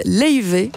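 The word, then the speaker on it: Laufey: On continue en musique avec l'Islandaise Leive, ça s'écrit Lofi, vous pouvez dire Lofi si vous voulez, elle est d'accord.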